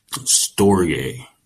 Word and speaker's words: storge: Um, the next is storge. Now it, it, it's spelled like store J like S T O R G E, but it's pronounced Storge.